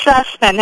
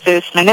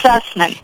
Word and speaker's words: Zussman: It's Zussman. Everybody says Zussman. It's Zussman.